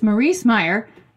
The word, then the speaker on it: Maryse Meijer: I had to ask how to say that. I was like, I'm going to screw this up. So it's Maryse Meyer.